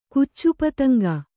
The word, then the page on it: ಕುಚ್ಚುಪತಂಗ: Kannada